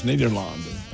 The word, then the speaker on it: Nederland: It does say Ned on there any day, and that's because the European pronunciation is Nederland. That's the Netherlands.